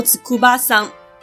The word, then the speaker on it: 筑波山: 筑波山念作子库巴桑。